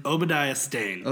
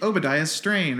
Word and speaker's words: Obadiah Stane: Obadiah Stane. Obadiah Stane. I always say like like Obadiah Strain or Stobadiah Lane or something, but Obadiah oh.